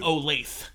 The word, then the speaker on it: Olathe: They're out of it's spelled Olathe, but they pronounce that it was the wedding food. – Olathe.